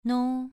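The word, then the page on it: nou: tone 2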